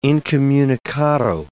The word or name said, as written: incommunicado